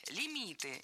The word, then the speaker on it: лимит: А подвох в том, что слово это латинское, а пришло в русский язык оно из французского и сохранило ударение французское на последнем слоге – лимит.